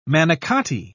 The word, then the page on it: manicotti: British English